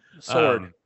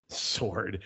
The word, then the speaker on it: Sjoerd: Sjoerd. Try to say that name. – Sword. – Sword.